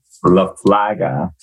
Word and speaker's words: LaFlaga: How do you say your last name? – LaFlaga. – LaFlaga. Cool, cool. So do you mind giving a brief introduction of yourself? – Hey, my name is Miro LaFlaga.